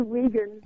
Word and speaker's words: Weigand: It's actually Wiegand, but Wiegand. It doesn't really matter. – Okay. I'm just – I see W-E-I, and I pronounce it like my own name, Weisberg.